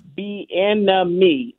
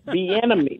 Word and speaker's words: Bieniemy: And one thing, you made me remind, the pronunciation guide is very important. Junkyard Dog. It's Eric Bieniemy. Let's say it together. Bieniemy. Let's say it all together.